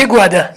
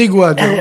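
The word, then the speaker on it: Ayguades: Comment prononcez-vous d'ailleurs à Gruissant? Vous dites les EGAD, les AIGAD? – Les EGAD. – Les EGAD.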